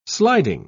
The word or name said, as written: sliding